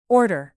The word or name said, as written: order